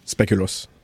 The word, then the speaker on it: Speculoos: Speculoos. How do they pronounce it? – Speculoos.